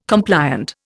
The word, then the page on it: compliant: Indian